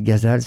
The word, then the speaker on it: Ghazal: Donc c'est presque comme Gaza, mais Ghazal, cette chanteuse, son nom s'écrit G-H-A-Z-A.